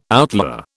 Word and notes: outlier: British English

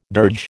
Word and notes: dirge: American English

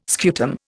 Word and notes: scutum: Indian

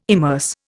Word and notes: immerse: Indian

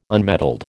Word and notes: unmetalled: American English

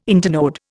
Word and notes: internode: Indian

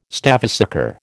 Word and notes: stavesacre: American English

